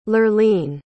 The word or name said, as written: lurleen